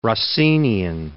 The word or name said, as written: Rossinian